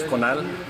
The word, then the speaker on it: Conal: Sí, mi nombre, primer nombre es Conal, se escribe C-O-N-A-L, apellido es Huetten, W-H-E-T-T-E-N.